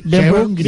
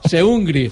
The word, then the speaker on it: Seungri: Que no Seur, Seungri. Bien pronunciado, bien pronunciado. – Seungri. – Se dice Seungri.